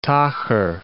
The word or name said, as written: tocher